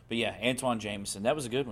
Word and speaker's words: Antoine: And his name is spelled weird, and I always wondered how to say it because you hear some people say Anton, you hear some people say Antoine. – Yep. – So you never know, but I guess his name is Antoine? – It is correct. I always pronounced it Anton as well, too, because of how it was spelled.